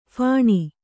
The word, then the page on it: ಫಣೆ: Kannada